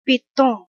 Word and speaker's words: piton: P-I-T-O-N. Google says piton. British. American. Piton.